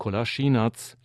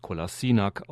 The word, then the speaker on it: Kolašinac: Einer der Bundesliga-Legionäre ist Sead Kolašinac, in Deutschland meist Kolasinac ausgesprochen, von Schalke 04.